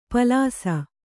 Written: ಪಾಲಿಸಿ

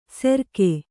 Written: ಸೆರ್ಕೆ

